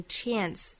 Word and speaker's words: chance: 后面的 chance 美音读成 chance，注意元音的变化。